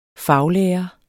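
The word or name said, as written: faglærer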